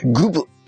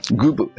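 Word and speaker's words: 供奉: グブ。あ、グブって読むのこれ。これは読めんな。グブ。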